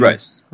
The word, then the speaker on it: Rhys: Matthew Rice. The actor, Matthew Rice. – Yeah, I'm just looking at R-H-Y-S. Maybe it's pronounced Reese. I'm not sure.